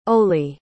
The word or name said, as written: Olie